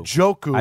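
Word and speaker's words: Njoku: Joku. – I believe the N is silent.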